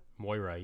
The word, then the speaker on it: Moira: New Overwatch hero reveals introducing, I'm probably going to say it wrong, but Moira? – Moira, yes.